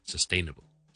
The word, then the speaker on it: sustainable: 一個好容易讀嘅方法，如果你仲係覺得難嘅話，先讀 sustain 先，sustain，好似 stay 咁 sustain，跟住讀 nable 就得，sustainable。 Sustainable, that's how you pronounce it.